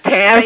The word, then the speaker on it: Tammy: I'm Tammy.